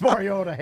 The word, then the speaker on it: Mariota: Marcus Mariota. – It's so funny how everyone's taking these quarterback rankings so personally. – At least I pronounce his name correctly.